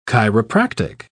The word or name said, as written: chiropractic